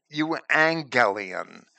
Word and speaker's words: euangelion: It comes straight from that Greek word for angelos, where the Greek, when it has a double G, the first G is said to have been pronounced as an N instead of a G. So, euangelion, or good angel, or good message is the literal translation, or good tidings, or gospel.